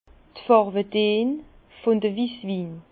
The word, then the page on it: d' Fàrwedeen vun de Wisswin: Bas-Rhin; Herrlisheim